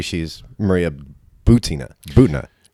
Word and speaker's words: Butina: I verified that it's Butina.